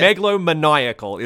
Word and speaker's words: megalomaniacal: Megalomaniacal is how that word is pronounced.